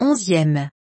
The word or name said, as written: 11eme